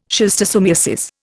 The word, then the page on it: schistosomiasis: Indian